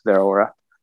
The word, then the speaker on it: Zeraora: Like just depending on what I'm feeling, I either run Absol or Zeraora. So I think I pronounced that right? Zeraora.